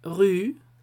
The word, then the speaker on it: rue: También tenemos la R con e, combinada con esa U y tenemos la palabra rue, que significa calle.